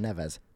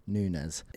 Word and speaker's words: Nunez: Nunez. – I keep getting his name wrong. – Nerez. – I keep saying Neves. Nunez.